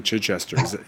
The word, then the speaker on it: Chichester: I always wonder if I'm mispronouncing Chichester.